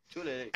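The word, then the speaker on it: Tiuliulin: I still didn't understand how to pronounce his name. – Tulelin.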